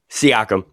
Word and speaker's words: Siakam: Siakam, thank you.